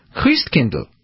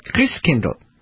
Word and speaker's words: Christkindl: Christkindl. That's right, Christkindl. What does that sound like? Kris Kringle. That's where the name comes from. The German pronunciation of Christchild, Christkindl, Kris Kringle.